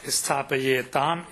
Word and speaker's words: स्थापयेत्: स्थापयेत् स्थापयेताम् स्थापयेयु स्थापयेः स्थापयेतम् स्थापयेत स्थापयेयम् स्थापयेव स्थापयेम अति उत्तमम् केवलं हिन्दी हिन्दी हिन्दी उच्चारणं नाम अस्थापयेत् अस्थापयेताम् इति नाम तत्र न